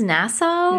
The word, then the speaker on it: Nassau: They're in Nassau, is that how you say it? Nassau? – I think it's Nassau. – Nassau.